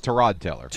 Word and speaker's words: Tyrod Taylor: Yeah, well, it's Tyrod Taylor.